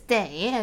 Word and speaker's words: 得: 好 usually, when it's pronounced as day, it means you have to, it is after the subject, right? – Yeah, that makes sense.